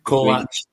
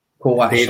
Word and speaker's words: Kolač: Tom Lawrence as well, and obviously, I know we've seen a brief bit of Chalak. Sorry, how do you spell it? I can't pronounce his name. – Kolač. – Kolač, sorry.